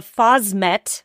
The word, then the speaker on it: phosmet: Blueberries, conventional non-organic blueberries, do come with the phosmet.